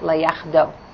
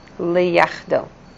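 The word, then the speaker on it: Le-yach-do (לייחדו): So in Hebrew that's called le-yach-do, from the word yachad. Lamed, yachad, vav. Lamed, yod, chet, dalet, vav. Le-yach-do.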